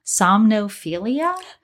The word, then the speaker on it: somnophilia: So if you want to explore like a kidnapping scenario, or even, I'm going to say this wrong, somnophilia, which I think you did a good job.